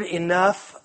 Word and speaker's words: enough: The word enough, the longer I've looked at it as I've been studying, the weirder it looks, because it ends with an F sound, but it's spelled G-H. So it should be eno or enow or I don't know.